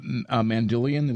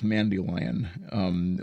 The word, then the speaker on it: Mandylion: And it's pronounced Mandylion. It's not pronounced Mandylion.